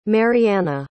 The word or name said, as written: marianna